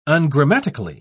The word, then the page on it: ungrammatically: British English